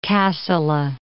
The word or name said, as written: Kassala